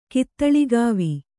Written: ಕಿತ್ತುಳಿಗಾವಿ

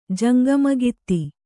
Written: ಜಂಗಮಗಿತ್ತಿ

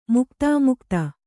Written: ಮುಕ್ತಾಮುಕ್ತ

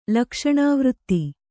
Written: ಲಕ್ಷಣಾವೃತ್ತಿ

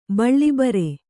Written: ಬಳಿಬರು

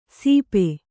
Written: ಸೀಪೆ

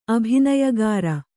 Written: ಅಭಿನಯಗಾರ

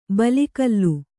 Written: ಬಲಿಗಲ್ಲು

